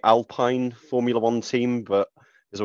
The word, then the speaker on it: Alpine: It's Alpine. – That sounds horrible. That sounds horrible. I'm just going to pretend it's like some cereal and it's Alpine because I'm ignorant, culturally ignorant.